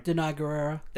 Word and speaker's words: Danai Gurira: Danai Gurira.